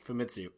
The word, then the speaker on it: Famitsu: And with the monthly Fatismu? Did I say that right? – Famitsu. – So I messed it up again. – Yep. – Fatismu and Famitsu. Okay, I got it.